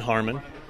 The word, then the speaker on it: Harman: Yeah, it's Ben Harmon, H-A-R-M-A-N for the last name.